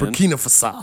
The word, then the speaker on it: Burkina Faso: Burkina Faso. – Okay. Well, you nailed it to me. – Okay. All right. I'm just saying we've thrown a coup every now and then. – Burkina Faso.